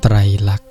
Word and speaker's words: ไตรลักษณ์: ไตรลักษณ์ไตรลักษณ์แปลว่าลักษณะ 3 อย่างไตรลักษณ์